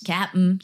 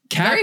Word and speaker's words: Cap'n: Cap'n. He said it right. Cap'n. Very few people say it right.